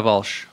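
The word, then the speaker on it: Walch: Elena Walsh, like Walsh. Like, spell it. – I think it's spelled the same way like the 90210 family was. Walsh. – Okay. W-A-L. – Yeah, okay. L-C-H.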